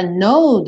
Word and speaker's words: know: E aí eu fiz questão de relembrar para você que essa palavra know do verbo saber ou conhecer tem exatamente a mesma pronúncia do know, do não, certo? É esse O fechadinho, né? Oh, oh, no. E o K é mudo, então é a mesma pronúncia, no.